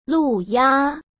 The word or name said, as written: Lu Ya